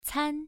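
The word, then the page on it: can: tone 1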